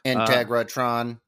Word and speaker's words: Integratron: Integratron. – You have to say it like that. Yeah. It's a rule.